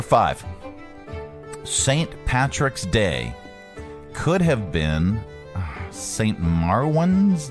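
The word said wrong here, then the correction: Maewyn: Day? I got to look for a pronunciation on this. Pronunciation. Thank you. Boom. Oh, man. Yeah, let's do this one. It's not the girl. All right, here. – Maiwin.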